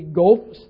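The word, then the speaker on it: Qoph: This is the Gulf stanza. That's how that word is pronounced.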